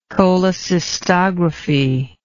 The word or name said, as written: cholecystogram